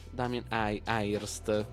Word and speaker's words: Damien Hirst: Damien Hirst.